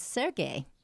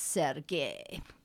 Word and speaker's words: Sergei: Well, you know, I was pronouncing Prokofiev's first name for a long time as Sergei. Or no, as Serge. – Serge, yeah. Mm. – Because sometimes it's spelled that way. – His friends called him that. – Well, then one lady came up and said, you know, it's supposed to be pronounced Sergei.